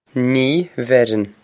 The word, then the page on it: Ní bheireann: Nee ver-on